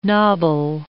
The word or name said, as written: nobble